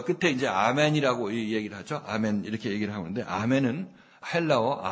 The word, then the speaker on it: 아멘: 헬라어 발음은 아멘인데 우리는 잘난 척하고 에이멘으로 바꿨구나 이렇게 생각하고 다시 이제 아멘으로 바꿀 모양이에요. 근데 우리는 에이멘으로 바꾸려고 하고 있는데 그래서 서로 어긋나는데 실제적으로 아멘이 맞는 거죠. 아멘. 아멘이 맞아요.